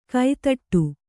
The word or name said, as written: ಕೈತಟ್ಟು